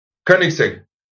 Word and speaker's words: Koenigsegg: Koenigsegg.